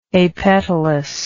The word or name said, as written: apetaly